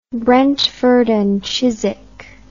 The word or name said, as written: brentford and chiswick